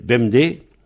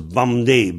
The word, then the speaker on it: bemdez: Et ça s'écrit en un seul mot. B-E-M-D-E-Z, BMD. Et en 21, on pense BAMD, BAM, BAMD. Mais ça s'écrit BMD. Et en Léon, il y a un Z à la fin, on dit BEMDES.